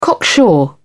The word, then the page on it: cocksure: British English